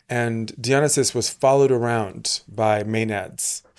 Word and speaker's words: Dionysus: So I say Dionysus because it feels more elegant to me, but I think the Brits say Dionysus.